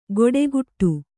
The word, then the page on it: ಗೊಡೆಗುಟ್ಟು: goḍuguṭṭu